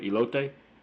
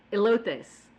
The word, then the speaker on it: Elote: Elote. – Elotes.